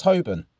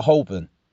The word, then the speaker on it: Holborn: You know it's pronounced Holborn, not Holborn. Yeah, Holborn. That's how it's meant to be pronounced.